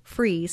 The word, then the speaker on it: Fries: Go to Sue, just like it sounds and spells, Sue Free, spelled like fries, like French fries. Sue Free, spelled like fries, one word, .com.